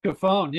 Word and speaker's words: Cofone: Cofone, yeah, you got it.